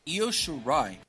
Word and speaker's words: Io Shirai: Io Shirai.